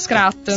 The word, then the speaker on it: Scrat: Hanno riciclato Scratch, il personaggio, no, Scrat. – Scrat. – Esatto. – Non l'ho mai imparato a pronunciarlo.